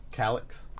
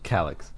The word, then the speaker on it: Calix: Calix. – Calix? Calix. Yeah. Calix? – Calix.